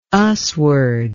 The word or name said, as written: usward